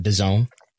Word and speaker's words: DAZN: not DAZN, but DAZN. Confusing branding there, but DAZN is the pronunciation apparently.